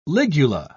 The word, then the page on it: ligula: British English